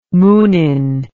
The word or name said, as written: munin